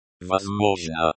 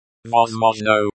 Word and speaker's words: Возможно: Возможно. Возможно, the stress falls on the second syllable.